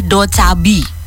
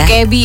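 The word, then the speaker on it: Dokebi (도깨비): Dan judulnya adalah Do Chabi. – Ya. Dokebi ya. – Do Chabi bacanya gimana? – Karena artinya goblin. Kalau goblin berarti dokebi.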